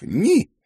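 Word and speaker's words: ni: Буква «П» и цифра 9, которая по-норвежски произносится как «ни», в сочетании вызывают прямые ассоциации со словом «пенис».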